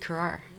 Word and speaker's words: krar: Yeah, well, you know, it is really cool to hear Kwankwa's recordings and to hear the sound of the Carrar, because Carrar, so you actually almost have a two-syllable word there, right? – Yeah. – Carrar. – Yeah, sometimes it's spelled K-I-R-A-R and sometimes it's spelled K-R-A-R.